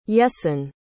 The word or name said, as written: Jessen